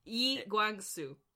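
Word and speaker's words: Lee Kwang-soo: um, Lee Kwang Soo. – Yi Guang Soo.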